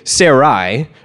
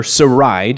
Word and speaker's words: Sarai: So Sarah, first name Sarai, or Sarai, depending on how you want to pronounce that, in Hebrew means my lady.